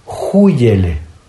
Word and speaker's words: júyele: júyele. – También la he escuchado. – Con J. Ajá. Con J, con acento en la U y con Y.